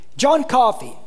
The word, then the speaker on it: John Coffey: John Coffey, you know, sounds like the drink, but not spelled like it.